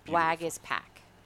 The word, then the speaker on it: Waguespack: Wagus Pack. – Not Wolf Pack. – No. – And it's it's pronounced Wag, is I S S, Pack. Wag is Pack if you have him on your daily fantasy from DraftKings out there and the Toronto Blue Jays in Buffalo, Harry.